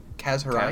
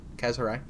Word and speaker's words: Kaz Hirai: Kaz Hirai. Kaz, Kaz Hirai. Yeah. Yep. You got it.